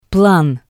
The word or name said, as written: plan